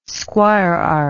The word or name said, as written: squirearch